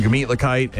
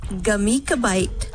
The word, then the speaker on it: Gemütlichkeit: Share some gemütlichkeit and hang out in the fest tent and yeah, enjoy themselves on a random Thursday morning.